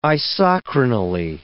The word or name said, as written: isochronally